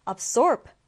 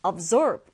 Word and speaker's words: absorb: 자, 이 단어는 디딤절의 강세를 두고요. 발음 두 가지 가능합니다. absorb 또는 absorb.